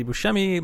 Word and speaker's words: Buscemi: And you can find me by Googling Andy Buscemi. Last name is B-U-S-C-E-M-I.